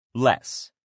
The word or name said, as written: less